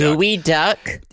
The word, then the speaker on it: geoduck: Gooey duck? – It's pronounced gooey duck, but it's spelled geoduck. – Geoduck. – G-E-O. – That's a Pokemon. D-U-C-K.